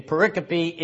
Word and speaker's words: pericope: Pericope. – Oh, pericope. Oh, I thought you'd be used to that by now. What's a pericope? – I'm doubting guilty of it. – P-E-R-I-C-O-P-E. Not pronounced pericope, pronounced pericope.